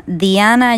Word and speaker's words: Dhyana: Hoy les hablo entonces de otro de los caminos del yoga que se llama Diana Yoga, así como el nombre mujer Diana, excepto que se escribe D-H-Y-A-N-A.